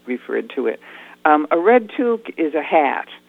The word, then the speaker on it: toque: I called it Toque. It's T-O-Q-U-E. Sounds to me like that's not how you pronounce it. And then part two is, what exactly is a toque? – I pronounce it toque, but I think either one is correct.